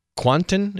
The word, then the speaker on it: Kwanten: Ryan Kwanten is how you say his name.